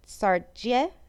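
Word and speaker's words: Saartjie: She was named Sarah Bartman, but she was renamed, I'm so sorry that I'm going to mispronounce this, Sartje. It's S-A-A-R-T-J-I-E. It's a diminutive form of Sarah in Dutch.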